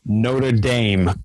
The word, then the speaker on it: Notre Dame: He's 6'7", second rower, who plays for, and I have to do this with the American pronunciation, which goes against all of my European heritage, Notre Dame College, which, he's you got it right, though.